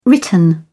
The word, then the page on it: written: British English